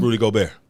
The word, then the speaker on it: Rudy Gobert: Rudy Gobert. Ain't that how you say his name? – Rudy Gobert.